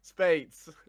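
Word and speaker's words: Speights: How do you say his last name? – Spates. – Spates. Okay, thank you.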